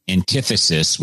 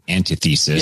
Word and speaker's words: antithesis: TKMG also says that antithesis, which is not how I pronounced it the first time. I said antithesis. – Yes. – Antithesis is the number one mispronounced word here on the podcast so far today.